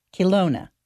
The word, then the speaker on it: Kelowna: Kelowna.